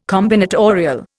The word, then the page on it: combinatorial: Indian